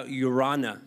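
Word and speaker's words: Araunah: So at this threshing floor, you'll see that this guy, Urana, that's how you say his name, even though it has an A at the front, it's Urana.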